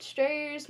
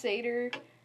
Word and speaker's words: satyr: So today, we're talking about strayers, minotaurs, and... I call them strayers, but people, it's pronounced satyr, but for this, I'm going to call it strayer, so just remember it's satyr, not strayer, but...